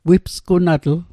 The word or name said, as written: wipsgunatl